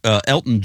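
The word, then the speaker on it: Elgton: Elgin Jenkins. – Elgin Jenkins, much like Elton John.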